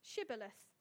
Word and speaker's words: Shibboleth: If the man said, No, I'm not, they would tell him to say Shibboleth. If he was from Ephraim, he would say Sibboleth because the people from Ephraim cannot pronounce the word correctly.